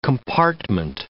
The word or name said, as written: compartment